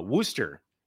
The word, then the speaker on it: Worcester: You go over to Worcester, Massachusetts, as it's so-called properly pronounced.